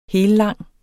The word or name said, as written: hellang